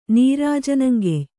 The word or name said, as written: ನೀರಾಜನಂಗೆಯ್